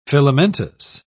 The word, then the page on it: filamentous: British English